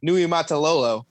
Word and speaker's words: Niumatalolo: Ken Niumatalolo is really good at finding who he wants at quarterback and has done so, I mean, since the Reynolds era.